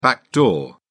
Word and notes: backdoor: British English

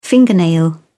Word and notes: fingernail: British English

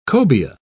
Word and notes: cobia: British English

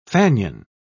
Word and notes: fanion: British English

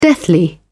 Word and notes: deathly: British English

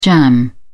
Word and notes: jamb: British English

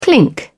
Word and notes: clink: British English

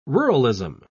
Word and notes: ruralism: British English

